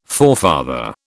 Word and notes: forefather: British English

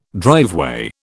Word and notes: driveway: British English

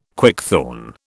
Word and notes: quickthorn: British English

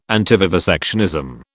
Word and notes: antivivisectionism: British English

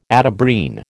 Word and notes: Atabrine: American English

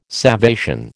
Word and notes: salvation: American English

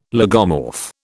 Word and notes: lagomorph: British English

